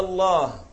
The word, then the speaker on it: الله: هلا هون عند اللفظ في ألف تلفظ، يعني بتقول يعني المد، بتقول الله، ما بتقول الله. هيدا المد لا يجوز أن تحذفه من لفظ الجلالة، يعني ما بتقول الله الله الله، هون طلعت الهاء أنت بس شو طيرت؟